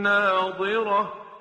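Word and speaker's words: ناظرة: وجوه یومئذ ناظره الى ربها ناظره، دقت بکنید تلفظ ضاد ناظره که در آیه 22 هست با ناظره که در آیه 23 هست خیلی تفاوت داره.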